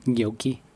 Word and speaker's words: gnocchi: Swallow the G. Gnocchi? – Gnocchi. – Okay. – The G is silent.